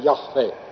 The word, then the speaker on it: Jahve: Tõenäoliselt õige hääldamine oli Jahve, aga nagu öeldud, päris kindlad meie ei saa selle juures olla.